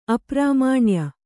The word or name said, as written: ಅಪ್ರಾಮಾಣ್ಯ